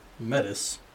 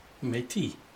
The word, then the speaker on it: Metis: And remember, Metis, not Métis. – You said it right.